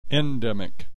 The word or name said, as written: endemic